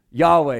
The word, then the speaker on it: Yahweh: What that YHWH, or sometimes we see it YAHW, it's spelled with letters. Yahweh is what we hear.